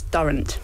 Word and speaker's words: Durrant: And my surname is Durrant. – Durrant. – It rhymes with current.